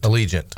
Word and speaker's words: Allegiant: How do you say that? Allegiant. – Allegiant. – Allegiant. Airlines. Like the stadium, yeah.